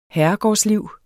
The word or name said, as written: herregårdsliv